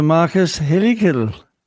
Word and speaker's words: Marcus Helligkilde: You're joined here and we've got a special guest, Mr. Marcus Helligkilde.